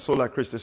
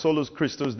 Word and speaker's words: Solus Christus: But Solus Christus is not pronounced as Sola Christus. It's pronounced as Solus Christus, then Solo de Gloria.